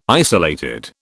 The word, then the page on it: isolated: British English